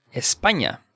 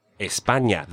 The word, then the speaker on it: España: España. – España.